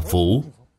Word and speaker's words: phủ: Chữ bất này đọc là phủ, chữ phủ trong khả phủ.